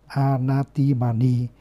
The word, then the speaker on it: anatimani: Suvacho chasa mudu anatimani. Anatimani thì gồm có a cộng với atimana, atimana, a cộng atimana thì đọc là anatimani.